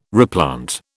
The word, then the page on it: replant: British English